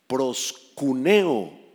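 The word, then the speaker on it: proskuneo: Proscuneo en griego, con K y acento en la U.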